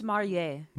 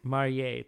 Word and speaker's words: Marillier: I think you already told Garance Marillier. Is that how you say your last name? – Garance Marier. – Marier, thank you.